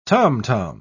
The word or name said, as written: tom-tom